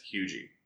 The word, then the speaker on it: Huger: It's not Huger. No. – What is it? – It's the R is silent, and it's Hugie.